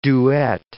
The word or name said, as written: duet